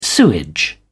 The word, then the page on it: sewage: British English